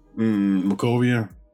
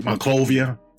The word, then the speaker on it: Monrovia: He's scanning the citizens of Monrovia, right? I'm pronouncing it right. – Monrovia. Monrovia. Yeah.